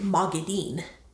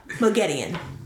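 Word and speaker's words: Moghedien: One of them pronounces it Mogedion. The other one pronounces it Mogedine. – Mogedine. – Oh, really? Yeah. – That's wrong. – That's the wrong one, for sure.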